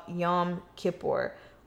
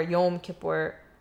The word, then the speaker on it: Yom Kippur: In today's society, you've probably heard the Day of Atonement called Yom Kippur, or Yom Kippur, depending on how you pronounce it.